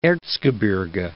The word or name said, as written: Erzgebirge